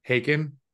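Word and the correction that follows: Håkon: Hakun, Hakun, Hakun. – Hakun, sorry, Hakun.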